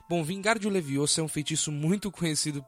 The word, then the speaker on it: Leviosa: E você está falando errado. É Leviosa. E não Leviosa.